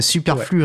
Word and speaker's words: Superflux Returns: Est-ce qu'il faut probablement prendre son bon gros accent français, Superflux ouais. Returns? – C'est exactement ça.